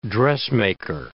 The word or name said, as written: dressmaker